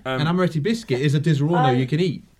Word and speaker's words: Disaronno: It's how it's spelt. It's Disaronno.